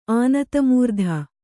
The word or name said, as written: ಅನಾದಿಮೂರ್ತಿ